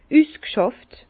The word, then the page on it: üsg'schàft: Bas-Rhin; Herrlisheim